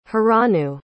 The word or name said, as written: haranu